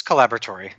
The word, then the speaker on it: Collaboratory: The Columbus Collaboratory. Yes. – You want to say collaboratory, but it's like collaboratory. Is it the British pronunciation or the English? – Yeah, that's the UK pronunciation.